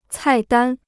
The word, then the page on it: 菜单: cài dān